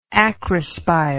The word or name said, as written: acrospire